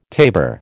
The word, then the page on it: tabor: American English